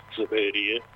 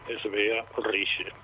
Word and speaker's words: Sverige: Los suecos, lo que nosotros decimos Suecia, ellos lo pronuncian o lo denominan Sverige, y Sverige es el reino de Svea. Svea Rige, se escribe Sverige.